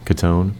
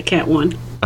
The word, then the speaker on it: Catone: So, Kelli and Josh, how do you pronounce his last name? – Catone. – Cat 1? – Yeah, Cat 1.